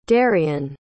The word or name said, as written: darion